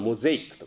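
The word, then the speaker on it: mosaic: あれは発音はモゼイクとか言うんだよね。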